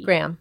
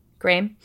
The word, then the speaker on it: Graeme: How would you say this name? G-R-A-E-M-E. – Graham. – Graham? Graham Thorne.